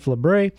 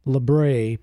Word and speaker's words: Labre: I'm using the common pronunciation of his last name, Labre. That's what it's commonly. It can be Labre and various other variations because we are an American-speaking people here, not French-speaking. So I'm going to go with the common Labre pronunciation of his name.